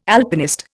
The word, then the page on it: Alpinist: Indian